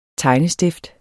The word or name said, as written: tegnestift